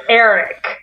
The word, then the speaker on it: Aeiriyk: It's like, it's spelled A-E-I-R-I-Y-K. – I can't believe that's pronounced Eric. Yeah.